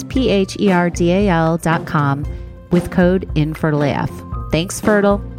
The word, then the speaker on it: Fertyl: That's Fertyl, pronounced Fertyl, but spelled with a PhD out of spite.